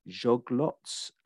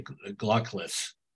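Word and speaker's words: Czolgosz: I'm saying Joglots, the person who shot McKinley in September 1901 and assassinated him. How do you pronounce it? – I've heard several different ones, but I'd say Glockless. – Glockless.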